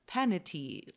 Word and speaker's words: Fainiti: On June 13th, we had Fainiti, which is spelled F-A-I-N-I-T, but pronounced Fainiti, like vanity.